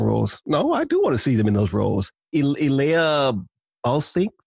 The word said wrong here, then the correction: Ella Balinska: I think that's how you pronounce her name. She's Ella Balinska.